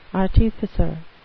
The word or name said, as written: artificer